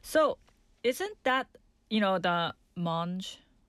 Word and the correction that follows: Montmajour: Montmajour. Yeah, Montmajour.